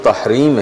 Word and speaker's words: تحریم: سلسلہ گفتگو کو آگے بڑھانے کے لیے میں سب سے پہلے سورہ تحریم، صحیح تلفظ تحریم ہے،